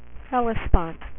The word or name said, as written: Helspont